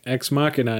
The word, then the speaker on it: Ex Machina: Until we just started recording, I was like, Ex Machina.